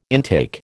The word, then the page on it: intake: American English